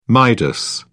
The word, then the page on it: midas: British English